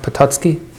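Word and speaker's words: Potocki: this story takes us back to Count Patucky, Patutsky, and uh will correct me for any, for any of the issues pronounced Pototski. Pototski. Pototski. Yeah. Count Pototski.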